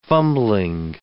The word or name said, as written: fumbling